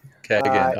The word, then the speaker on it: Keegan: So most people pronounce the name Keegan, but the traditional Irish pronunciation of the name is Kegan. So Kegan.